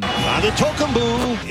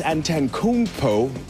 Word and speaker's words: Antetokounmpo: Antetokounmpo. It's official NBA All-Star Giannis Antetokounmpo.